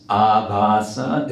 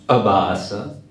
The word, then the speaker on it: abhasa: The word is abhasa. It's not abhasa. So it's nam abhasa. It's a long A.